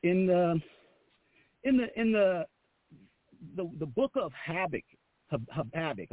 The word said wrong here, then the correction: Habakkuk: I always say that wrong. Habakkuk is his name.